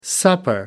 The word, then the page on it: supper: American English